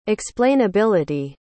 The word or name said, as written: explainability